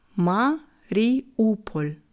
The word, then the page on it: Mariupol: slow